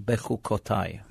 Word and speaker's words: Bechukotai: Behar and Bechukotai.